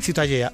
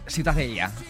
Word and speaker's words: Ciutadella: ¿cómo se pronuncia? – La Ciutadella.